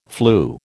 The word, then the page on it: flew: American English